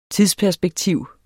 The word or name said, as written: tidsperspektiv